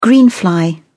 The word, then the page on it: greenfly: British English